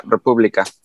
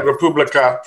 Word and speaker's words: República: And did I pronounce that properly? – Yeah, República. You got it. – Right, República.